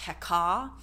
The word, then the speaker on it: Heka: Which is funny because Heka actually means it's using the, like it's actually probably pronounced Heka because it is the magic of Ka.